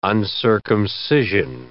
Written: uncircumcision

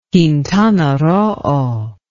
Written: Quintana Roo

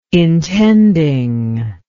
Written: intending

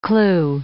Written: clew

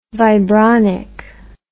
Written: vibronic

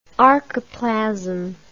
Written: archiplasmic

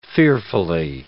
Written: fearfully

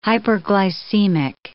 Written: hyperglycemic

